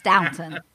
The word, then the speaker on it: Downton: Not downtown. That, yeah. Is it downtown Abbey? Downtown Abbey. – It's Downton. That's Downton. – Oh, it's not downtown? – No, it's Downton.